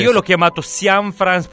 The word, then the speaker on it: Cianfrance: E ho scoperto che, contrariamente a quello alle mie aspettative, si pronuncia Cianfrance.